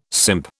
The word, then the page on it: simp: British English